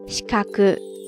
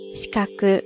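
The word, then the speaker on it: 視覚: 然后视觉呢是 shikaku shikaku shikaku，听觉呢是 chokaku chokaku chokaku ですね。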